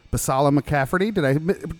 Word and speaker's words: Basalla-McCafferty: I'm with Leslie Basalla-McCafferty.